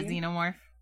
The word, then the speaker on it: xenomorph: Would you rather make out with a xenomorph? That's how you say it, right? – Yeah, a xenomorph.